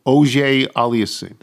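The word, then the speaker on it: Auger-Aliassime: Oje Aliassime.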